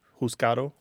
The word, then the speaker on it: juzgado: And then juzgado, the Spanish is JUZGADO, right? Juzgado. – Mm-hmm. Mm-hmm. – But in some Spanish dialects, like you said, the D is not pronounced, so it sounds like juzgado.